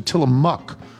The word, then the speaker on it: Tillamook: I was recently on the county's tourism board, and the Tillamook Creamery, pronounced closer to Tillamook, with the O-O being closer to a short U vowel, is our biggest draw of tourists every year.